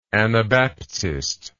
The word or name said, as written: anabaptistically